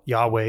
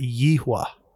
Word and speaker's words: Yahweh: So how you pronounce that, it can be people pronounce it different ways. Yahweh is one way. Digging in recently, I found another way. A gentleman talked through why, and he says that he pronounces it Yehwah.